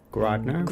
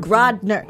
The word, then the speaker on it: Grodner: I have director Michael Grodner, Grodner. Grodner.